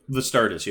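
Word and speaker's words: Vastardis: Particularly, you pointed out what Bastardus did on that, the one snap. Did I pronounce his name correctly? – Vistardis, yeah. – Yeah, yeah. – With a V.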